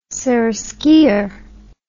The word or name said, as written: seraskier